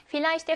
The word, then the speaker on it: Vielleicht: 자, 그러면 Vielleicht V는 F 발음을 냅니다.